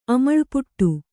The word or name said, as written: ಅಮಳ್ಪುಟ್ಟು